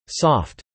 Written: soft

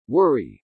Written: worry

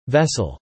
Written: vessel

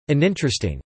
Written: uninteresting

